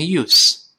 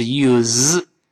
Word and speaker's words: use: 首先，第一个 use 这个 use，好多人容易把它读成 use。我们知道名词读成 use，动词是 use，是 z，这是个浊辅音。